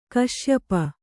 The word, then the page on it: ಕಾಶ್ಯಪಿ: kāśyapi